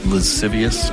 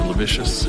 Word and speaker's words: lascivious: lascivious or lavicious, or I don't know which pronunciation is right. – Lascivious.